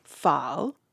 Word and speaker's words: pho: I still say pho. I'm not supposed to say that. I know it's wrong. So what should I call it? – Pho.